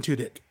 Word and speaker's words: Tudyk: His name is Alan Tudyk.